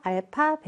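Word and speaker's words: 알파 (α): 알파, 베타라고 읽어주시면 되고, 우리 방정식에서의 근을 표현하는 그리스 소문자다라고 그냥 생각하시면 됩니다.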